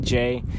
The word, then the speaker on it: Jay: Just say call me Jay, and that's it. It's not hard. It's it's, it's just simple. It's a one fucking syllable.